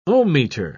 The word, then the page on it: ohmmeter: British English